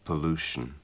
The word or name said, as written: pollution